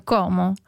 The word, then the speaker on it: Como: che noi, tutti quanti, i miei conterranei dicono Como, ma la pronuncia in italiano è Como, anche Como. Perché pure in dialetto è Com, con la O aperta,